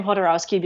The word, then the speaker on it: Jodorowsky: Yeah, I have, you know, that documentary in particular really solidified my opinion of, and it's interesting, I guess, are you pronouncing his name Jodorowsky because he grew up in Argentina and that's how he pronounces it? – That's the way that I've always heard it pronounced, but, okay, that makes sense.